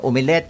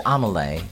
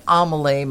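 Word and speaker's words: omelette: O di kaya naman, sa tawag nating mga Pilipino, umilet. I-umilet mo nga yan. But actually, the correct pronunciation is omelay. Can you please omelay my tabioids?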